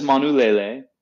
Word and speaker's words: Manulele: So that is Manulele. For those following at home, it's spelled M-A-N-U-L-E-L-E, and the iteration that we're trying today was harvested in July of 2019.